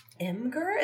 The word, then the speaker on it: Imgur: Imgur? Is that how you pronounce that? – I know. Isn't that ridiculous? – Is that really how it's pronounced? – It is how it's pronounced.